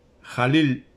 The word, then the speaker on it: Jalil: Se pronuncia así, ¿ah? la KH es J. Jalil Gibran.